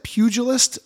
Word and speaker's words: pugilist: What is that, pugilist? Is that how you pronounce boxers? I've always seen the word, but I've never said it in my life. – That's the word.